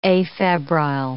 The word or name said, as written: afebrile